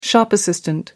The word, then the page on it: shop assistant: American English